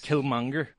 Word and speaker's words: Killmonger: Did I pronounce that correctly? Killmonger? – Yes. – Killmonger.